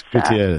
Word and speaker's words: Gutierrez: And, you know, it's spelled Gutierrez, and I just don't have that Latin flair say it right. – Gutierrez.